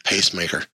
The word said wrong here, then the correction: Peacemaker: I even saw the E, but didn't want to pronounce it. – That was good. – Peacemaker and the Penguin.